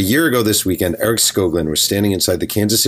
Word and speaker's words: Skoglund: Is it Eric Skoglund? Is that how you pronounce it? – Yep. – All right. Eric Skoglund.